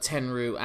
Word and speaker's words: Tenryu: And it's, in case you're curious, it's Tsuruta, Tenryu,